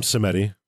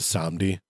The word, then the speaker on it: Samedi: We call them Semeti, but they're probably more aptly pronounced as Samedi.